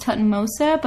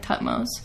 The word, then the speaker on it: Thutmose: It can also be pronounced Tutmose, but I'm sticking with Thutmose.